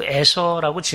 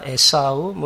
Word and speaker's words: 에서: 예, 그래서 이름을 그 에서라고 지은 것입니다. 히브리 발음으로 이제 아마 에사우 뭐 이런 식으로 발음이 되는데 이 히브리 단어의 뜻 자체가 털이 많다.